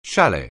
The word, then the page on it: chalet: anglais